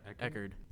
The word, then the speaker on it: Eckhart: Aaron, I don't know how to pronounce his last name. Eckerhart. – Eckhart? Yeah, Eckard.